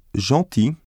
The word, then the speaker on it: gentil: One exception to this rule is gentil, where the IL is pronounced I.